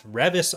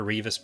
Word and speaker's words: Revis: So the topic for today's episode is The Princess and the Scoundrel, the new book by Beth Revis, I think is how you pronounce her last name. – Yeah, that's correct. – I'd been calling her Revis before, so that was incorrect.